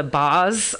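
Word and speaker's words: Baahs: It's, I always say it wrong, B-A-A HS, so it's the Boz company.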